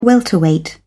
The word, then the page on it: welterweight: British English